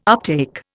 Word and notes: uptake: Indian

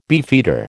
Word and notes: beefeater: American English

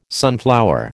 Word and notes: sunflower: American English

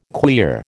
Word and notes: queer: American English

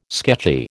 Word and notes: sketchy: American English

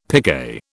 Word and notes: picquet: British English

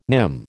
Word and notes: nim: American English

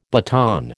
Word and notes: baton: American English

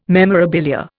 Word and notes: memorabilia: Indian

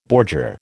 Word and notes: bordure: American English

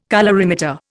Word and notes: colorimeter: Indian

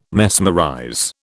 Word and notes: mesmerize: British English

